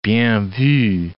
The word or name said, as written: bien vu